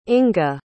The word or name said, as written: inga